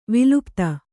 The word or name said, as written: ವಿಲುಪ್ತಿ